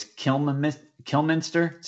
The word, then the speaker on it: Kilminster: I think I said his name wrong. It's Kilminster. So Dave Kilminster.